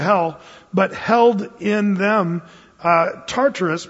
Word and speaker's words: Tartarus: The word is Tartarus. If you spell it in the English transliteration, it's T-A-R-T-A-R-U-S. Tartarus.